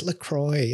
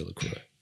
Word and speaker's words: LaCroix: Like LaCroix, I kept calling LaCroix, and then somebody just like very snappily, like, it's LaCroix. I'm like, okay. So now I say LaCroix.